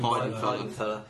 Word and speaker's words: Weidenfeller: Weidenfeller.